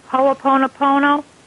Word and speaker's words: Ho'oponopono: Ho'oponopono? – Yes, that's it.